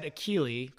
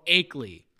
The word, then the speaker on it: Akeley: I said Achille, Carl Achille. It's Carl Akeley.